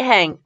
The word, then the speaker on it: Hange: Victor, Yuri, Otabek, meet Hang meet Hangy. Hangy I can never say her name. H-A-N-G-E, hang. Hang, we'll call her that.